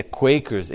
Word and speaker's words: Quakers: não é a pronúncia que normalmente as pessoas dão, né? Mas né, em inglês é Quakers.